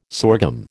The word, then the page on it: sorghum: American English